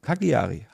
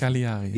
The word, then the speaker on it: Cagliari: Cagliari. – Ja, genau. Cagliari, habe ich gesagt, ne? – Cagliari. – Cagliari. Ja, ja. Deswegen, der Name bleibt in meinem Gedächtnis, aber falsch ausgesprochen.